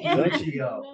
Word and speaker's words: Nuccio: Nuccio.